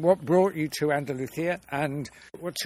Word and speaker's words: Andalusia: But you tell me that you actually live in Andalusia.